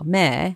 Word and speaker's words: Mair: I'm going to move on to, how do I pronounce this name? It's spelled M-A-I-R, Mare.